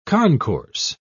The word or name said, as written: concourse